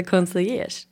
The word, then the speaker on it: concierge: Konsertgör, ja. – Konsertgör. – Okej. – Okay. Säg du. – Nej, nu vågar inte längre. jag tycker att det heter concierge. – Consigliers. – Concierge.